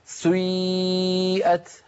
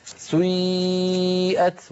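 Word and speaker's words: سِيئَتْ: سيئت على وزن فعل، لأن هذه الأفعال سيئة وأخواتها أفعال مبنية للمجهول، فبعض القبائل العربية كانوا ينطقونها بيبدأون بضمة ثم يعودون إلى الخلف فينطقون ياء هكذا. سيئت هذه الحركة يسميها العلماء حركة مركبة، مركبة يعني من حركتين أولها ضمة وآخرها كسرة كما نطقتها لك.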